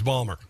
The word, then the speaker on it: Baltimore: No, everybody in and around Baltimore says Balmer.